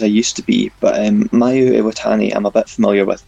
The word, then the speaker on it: Mayu Iwatani: Mayu Iwatani. Is that how you pronounce it? – Yeah, spot on.